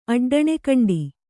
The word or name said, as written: ಅಡ್ಡಣೆಕಂಡಿ